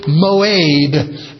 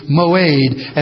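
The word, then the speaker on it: moed (מועד): That word seasons is important because it's the Hebrew moed, M-O, long E, pronounced A in Hebrew, moed.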